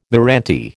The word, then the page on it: meranti: American English